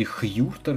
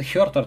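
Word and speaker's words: Huerter: Х- или Хьюртер, говорят якобы комментаторы НБА там на каких-то каналах. Ребята, Кевин Хертер, так говорят американские комментаторы, мне это произношение кажется оптимальным.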